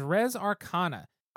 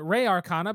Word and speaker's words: Res Arcana: It is Res Arcana. I always pronounce it Re Arcana because of Republica, and maybe it needs to be Res anyway. Looks like it's kind of depending on who you ask.